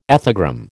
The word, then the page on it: ethogram: American English